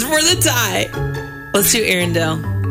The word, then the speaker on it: Arendelle: I think it's Arendelle. That's the second one, right? That Correct.